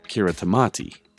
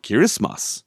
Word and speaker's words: Kiritimati: It's in Kiribati, so you might think it's pronounced Kiritimati. It's actually pronounced Kirismas.